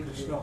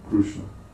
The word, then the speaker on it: Krishna: Because you're Oriya, so the vocalic R is pronounced by the Oriyas as Ru. Krishna.